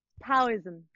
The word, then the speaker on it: Taoism: Taoism.